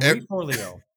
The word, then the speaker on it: Forleo: Marie Forleo. – Yes. Well said. Marie Forleo.